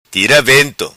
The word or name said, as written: Tira Vento